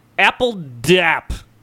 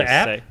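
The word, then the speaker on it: apl.de.ap: Taboo and, oh God, how do you pronounce that? Apple Dap. APL period DE period AP.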